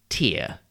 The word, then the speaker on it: tear: T-E-A-R is pronounced tear when it is something that comes out of your eye when you cry. But if you pronounce it tear, it means to pull something apart by force. And tear rhymes with pair, P-A-I-R, meaning two of something, and pear, P-E-A-R, that delicious fruit, and P-A-R-E, pear, which is a slightly strange word, meaning to cut away the outside of something.